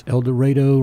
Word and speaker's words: El Dorado: So tell me about your current role, El Dorado. Tell me what that's all about. – Well, first, I have to correct you. It's El Dorado, rhymes with tornado.